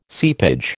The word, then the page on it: seepage: American English